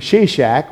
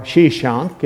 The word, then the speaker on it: Shishak: We learn something about the excursion that was taken by this king, Shishak, or Shishank, it's sometimes pronounced in Egypt.